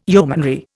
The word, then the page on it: yeomanry: Indian